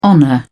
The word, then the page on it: honour: British English